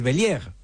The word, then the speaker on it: Bellieres: Se escribe Bellieres, pero es Bellier, ¿no?